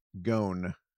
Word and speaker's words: Ghosn: Gon. It's spelled G-H-O-S-N. – Gone, is it like Gone with the Wind?